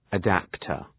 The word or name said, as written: adaptor